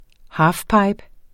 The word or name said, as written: halfpipe